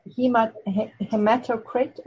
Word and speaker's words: Hematocrit: Hematocrit.